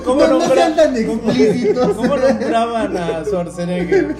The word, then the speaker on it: Schwarzenegger: Oh, sí, o sea, porque, porque realmente el pronunciar el Schwarzenegger de repente sí era mi mamá, complicado. Mi mamá le dice Chagernager.